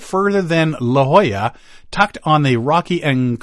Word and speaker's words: La Jolla: I looked at it and thinking, oh, La Jolla, La Jolla, California. No, La Jolla.